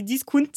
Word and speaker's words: discount: Donc, comment on le dit plutôt euh, je pense qu'on dit discount. Discount, c'est exactement ça.